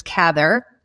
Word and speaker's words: Cather: emphasized that it be it, it was Cather, and it would be pronounced in a similar way to rather.